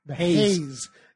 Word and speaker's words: Heizz: No, they're called the Haze. It's spelled H-E-I-Z-Z.